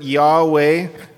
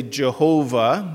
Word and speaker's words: Yahweh: We transliterate it as W-A-W or sometimes V-A-V. And as a connection, if you've seen the name for God written out, mostly scholars now would pronounce it Yahweh. Historically, a lot of people would say Jehovah.